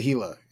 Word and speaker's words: Gila: And you better say Gila. It's if you pronounce the G, oof, you strap in.